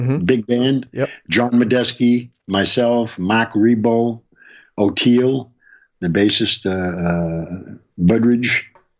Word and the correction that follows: Burbridge: That's how you pronounce his name, I think. – O'Teal Burbridge, yeah.